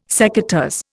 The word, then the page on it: secateurs: Indian